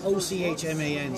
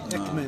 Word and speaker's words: Ochman: It's okay because actually my name gets it, it's O C H M A N, so it gets mispronounced all the time. Ochman and, and Ekman and Ukman.